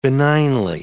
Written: benignly

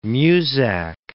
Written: Muzak

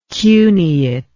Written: cuneately

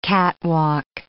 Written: catwalk

